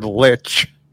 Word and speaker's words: lich: Rurik is trying to change the pronunciation of this word. The pronunciation of the word lich. – Lich. – To leech. Leech.